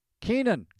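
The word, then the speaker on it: Kaenon: That's K-A-E-N-O-N, Cast15. Keenan, clearly better.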